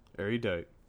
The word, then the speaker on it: Erudite: Erudite.